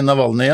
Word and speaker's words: Navalny: den russiske opposisjonspolitikeren Navalny. Er det sånn man uttaler det? – Ja, jeg er ikke så veldig god på russisk, men jeg tror vi sier Aleksej Navalny, ja. Eh